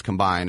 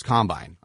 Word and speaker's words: combine: And remember, in football, you put the emphasis on the first syllable. It's not offense, defense, combine. It's offense, defense, combine.